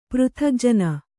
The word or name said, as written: ಪೃಥಗ್ಜನ